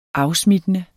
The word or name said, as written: afsmittende